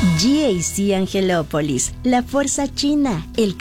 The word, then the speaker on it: GAC: Se escribe GAC. pero se pronuncia GAC. Repítelo, GAC Angelópolis, donde el tráfico termina y el manejo empieza.